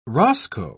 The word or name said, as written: roscoe